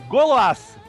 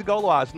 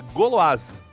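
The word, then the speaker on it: Gauloises: E só pra constar, caros amigos, eu tava até colando aqui no chat, a pronúncia correta da marca de cigarro é Golas, tá? – Nossa. – Significa mulher gaulesa. Olha que... Mulheres gaulesas. Esse é o negócio. E Gitan era o nome da outra marca de cigarro. Né? Eu sempre falando Gitanes e Gauloase. Não, é Goloase.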